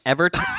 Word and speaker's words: Evert: It is. I'm actually pronouncing it right. Evert fresh. – Whoa. – Green bags. – E-V-E-R-T. – E-V-E-R-T.